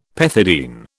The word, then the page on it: pethidine: British English